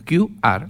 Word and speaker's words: QR: QR, así se pronuncia el grupo. QR.